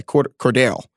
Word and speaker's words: Cordarrelle: Next for me, so Cordarrelle Patterson, I'm speaking of names I can't say, Cordarrelle. – I think, yeah, that's how you say it.